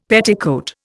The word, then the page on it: petticoat: Indian